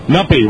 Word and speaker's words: Nuppy: Nuppy rhymes with puppy.